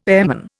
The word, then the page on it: airman: Indian